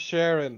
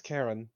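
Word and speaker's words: Charon: it's not said sharon or it's it's okay how do you how do you pronounce the Wiverstuck guy it's karen Karen.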